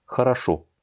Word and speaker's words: хорошо: Хорошо, l'accent tonique est sur le troisième O, le dernier, la dernière syllabe, et donc les deux premiers n'étant pas accentués vont se prononcer comme des A. Хорошо.